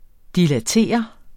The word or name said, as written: dilatere